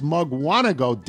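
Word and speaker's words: Mukwonago: In fact, you incorrectly pronouncing it on this podcast is the most attention it's received since winning the high school football championship in 2004. It's Mugwanago, damn it.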